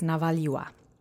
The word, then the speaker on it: navaliwa: If it's female, it's navaliwa. Okay? So let's say navaliwa, just three times real quick. Navaliwa. Navaliwa. Navaliwa. Notice the accent changes to the penultimate syllable.